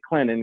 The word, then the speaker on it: Clinton: Then we kind of say the joke around here that, you know, people don't pronounce the T in Clinton. They say Clinton because, you know, we're kind of Southern North Carolina here.